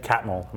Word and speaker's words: Catmull: When people come together, Ed Catmull, am I pronouncing that correctly? – Mm-hmm.